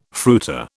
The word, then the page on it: fruiter: British English